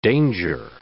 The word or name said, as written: danger